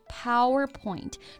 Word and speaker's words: PowerPoint: Right, PowerPoint。注意啊，重音是在第一个音节。